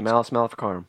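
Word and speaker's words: Malleus Maleficarum: Yeah, the Malus Maleficarum.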